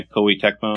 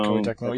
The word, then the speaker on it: Koei Tecmo: Hyrule Warriors Legends, which was leaked, you know, ahead of time by accident by Team Koei, is that how they pronounce it? – Yeah, Koei Tecmo. – Koei Tecmo, yeah, that's right.